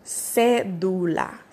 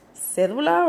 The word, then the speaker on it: cédula: But here, when you talk about an ID, it's called cédula. Cédula, right? So cédula is C-E-D-U-L-I-A. C-E-D-U-L-A.